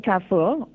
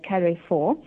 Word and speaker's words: Carrefour: Okay, so I've chosen Carrefour, or as my colleagues some say, Carrefour.